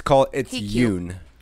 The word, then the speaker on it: Yoon: Oh, and it's Yoon. – Yoon. – Y-O-O-N is how it's pronounced.